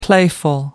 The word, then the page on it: playful: American English